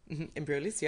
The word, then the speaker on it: Embryolisse: You've got, forgive my terrible pronunciation, particularly given your astounding French, Embryolis. – Embryolisse, yep. – Embryolisse moisturizer, the Bioderma micellar water, mm-hmm.